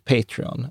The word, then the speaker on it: Patreon: Jag har fått jättemycket kritik för mitt uttal av det här. Yes. Eh, ja, att det heter Patreon.